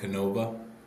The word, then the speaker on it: Canova: So just by a quick way of introduction, we have Dan, am I pronouncing this right, Canova? – Yep.